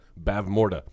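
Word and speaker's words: Bavmorda: Bav Morta, which is right. Is that, did I say her name right? – Yeah, she's the kingdom of Nakmar, the evil sorceress who is ruling the land and causing a lot of distress amongst the peeps.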